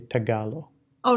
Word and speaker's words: Tagalog: I read a Tagalog and I think people would get offended. So they say Tagalo. – Oh, really? Yeah. Tagalo. Okay. – It's spelled Tagalog.